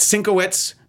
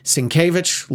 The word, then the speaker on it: Sinkowitz: And we examined this with the media studies professor from Boston College, Matt Sinkowitz, not pronounced Sinkevich, like the artist of the similar spelling, the same spelling.